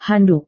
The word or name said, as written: handuk